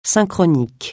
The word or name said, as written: synchronique